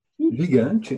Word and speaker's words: Gigante: Et donc, pour écouter un morceau de ton dernier album, moi, je propose d'écouter, parce que je l'aime particulièrement, après, je le prononce mal, Gigante. – Gigante, ouais, très bien.